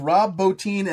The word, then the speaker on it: Bottin: A gentleman by the name of Rob Bottin, and that is how Carpenter pronounces it, so I'm going with the way Carpenter says it.